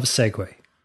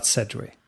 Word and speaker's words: Segway: I'm so thankful that someone mentioned the pronunciation of Segway, not Sedgway.